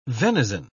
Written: venison

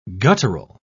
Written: guttural

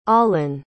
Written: Olen

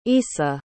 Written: essa

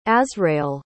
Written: azrael

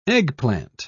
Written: eggplant

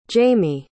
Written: jaymee

